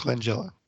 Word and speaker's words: Langella: Frank LaGaglia that was the. – Frank Langella. – Langella? – Yeah.